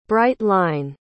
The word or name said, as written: bright-line